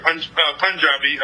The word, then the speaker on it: Punjabi: Punjabi. Basically, my background is Punjabi, the state of Punjab, which half is in Pakistan and half is in India, after the split. But my background is Punjabi.